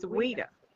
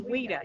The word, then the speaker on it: Oeida: It's Oeida. – Oeida. – Yes, it starts with an O, but it's Oeida. It's French.